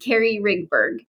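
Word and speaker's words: Kelly Rigberg: But did I say your last name right or did my accent best me? – Absolutely. Carrie Rigberg. Yes.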